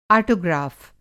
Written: आटोग्राफ़

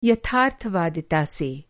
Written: यथार्थ-वादिता

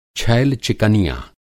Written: छैल-चिकनिया

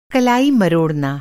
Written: कलाई

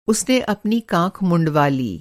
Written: काँख

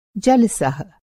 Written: जलसह

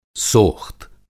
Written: सोख़्त

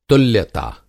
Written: तुल्यता

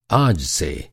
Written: आज

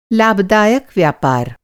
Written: लाभदायक

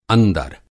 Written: अंदर

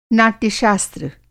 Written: नाट्यशास्त्र

